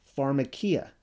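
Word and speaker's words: pharmakeia: I took the liberty of adding the word drug to sorcery, because the Greek word is pronounced pharmakia.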